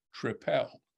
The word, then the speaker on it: tripel: In fact, I probably said I'll have a triple when I spoke to him, and he then said tripel.